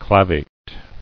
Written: Clavated

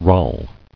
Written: Rale

